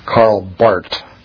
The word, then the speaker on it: Barth: You say it the German pronunciation of the TH, which is just a T sound. So Karl Barth or Barthianism.